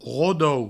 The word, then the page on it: rodo: rodou; Provençal